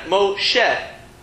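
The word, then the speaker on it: Moshe (משה): And probably the most famous example is Moshe, right? Moshe. You don't really pronounce that last letter, okay?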